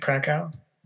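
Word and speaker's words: Kraków: That's how you say it, Krakow? – Mm-hmm.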